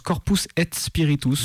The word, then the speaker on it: Corpus et Spiritus: Comment ça se prononce exactement? – Moi, je le prononce Corpus et Spiritus. – Donc en latin.